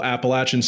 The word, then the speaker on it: Appalachian: You got that at good old Appalachian State University down there in Boone, North Carolina.